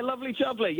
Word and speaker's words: Lovely Jubbly: but I am going to ask you about Lovely Jubbly. Am I saying that correctly? What am I saying there? – Yeah, Lovely Jubbly.